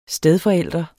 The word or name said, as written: stedforælder